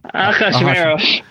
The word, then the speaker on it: Ahasuerus: Ahasuerus.